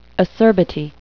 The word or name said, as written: acerbity